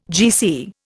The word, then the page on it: GC: Indian